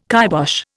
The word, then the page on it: kibosh: Indian